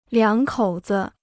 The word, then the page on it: 两口子: liǎng kǒu zi